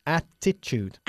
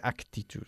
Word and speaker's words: attitude: Attitude. – Attitude. – Bien. No se dice actitude, ¿ok? Attitude en inglés.